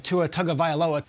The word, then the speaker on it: Tua Tagovailoa: First, I want to shout out Richard for the pronunciation of Tua Tagovailoa.